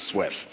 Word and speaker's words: SWF: Follows a specification called SWF, or apparently pronounced Swift.